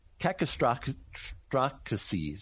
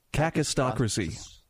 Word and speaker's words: kakistocracy: Kakistocracy. See, I messed it up. – Kakistocracy.